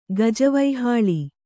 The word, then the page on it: ಗಜವೈಹಾಳಿ: Kannada